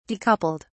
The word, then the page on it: decoupled: British English